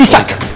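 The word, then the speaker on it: Isak: ¿Se pronuncia Isaac? – Isaac.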